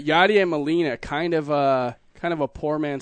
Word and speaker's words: Yadier Molina: Yadier Molina.